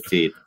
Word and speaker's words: Veth: Yeah, you can find me on Twitter at my name, at Manuel Veth. – I've now realized I've been pronouncing completely wrong, and corrected actually, me, which is great. – It's actually Manuel Feet, but that's okay.